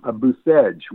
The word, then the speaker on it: Bucegi: I'd like to also, because this show is coming to you live from Brașov, Romania, which is not far from Buceg, which is spelled B-U-C-E-G-I.